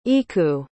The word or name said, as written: iku